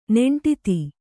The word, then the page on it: ನೆಂಟಿತ್ತಿ: neṇṭitti